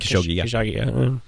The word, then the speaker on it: Khashoggi: Khashoggi.